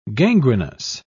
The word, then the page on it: gangrenous: British English